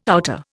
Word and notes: outer: Indian

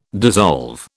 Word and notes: dissolve: British English

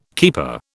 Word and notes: keeper: British English

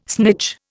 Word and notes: snitch: Indian

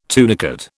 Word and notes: tunicate: British English

